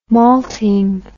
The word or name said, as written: malting